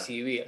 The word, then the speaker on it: ECBY: y B es como en inglés por, pero yo la pronuncio, la pronuncio como, como está escrito. Claro. ACB, así.